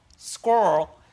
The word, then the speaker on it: squirrel: The correct pronunciation for squirrel is squirrel. It is. Let me say this. – It's two syllables.